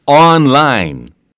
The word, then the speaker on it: online: 발음 하나만 주의하세요. 이거 온라인? No, no, no. 온라인? No, no, no. – Yeah, I would say it like this. On. – 맞습니다. – Online. – 네, 그러니까 on까지 하시고 뒤에 그냥 랄랄라를 붙이세요.